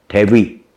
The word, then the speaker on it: debut: 在经过一次一次的很艰难的 audition 啊，就是试镜啦啊， audition，经过 audition 以后呢，才能成为这个后来的 idol，才有机会 Debut 就是出道的意思啊，它的发音很像那个英文名字 David 啊，Debut。